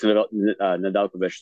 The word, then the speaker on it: Nedeljkovic: One that I see here, and it's very interesting, is I'm hoping I'm pronouncing this right, Nedeljkovic. – Mm-hmm. Yeah, Alex Nedeljkovic, the former Rookie of the Year that was traded from Carolina to Detroit.